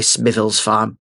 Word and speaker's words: Smithills Farm: And so, I have indeed been pronouncing the name of Smith Hills Farm incorrectly. It is actually Smithills Farm, and I hope that satisfies the people out there that I've written to me with endless, endless letters.